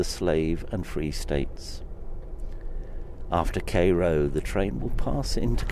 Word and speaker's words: Cairo: As the night progresses, we will pass through Cairo, which is pronounced Cairo, a river port at the junction of the Ohio and Mississippi rivers.